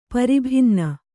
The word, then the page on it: ಪರಿಭಿನ್ನ: pari bhinna